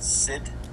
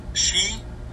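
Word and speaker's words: sidhe: It is pronounced just like you say she to a woman.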